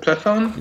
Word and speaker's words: Plethon: Beginning with Plethon, is that the correct pronunciation? – Gemistos Plethon? – Yes.